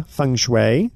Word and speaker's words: Feng Shui: Feng Shui.